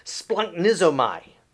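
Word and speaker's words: splunknizomai (σπλαγχνίζομαι): And that Greek word can be pronounced splunknizomai. Splunknizomai.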